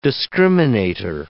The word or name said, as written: discriminator